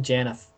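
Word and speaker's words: Janaff: All right, how about Janif, Janaf? – From Nine? – Yeah. – I pronounce it Janif. – Janif, that's what I say too.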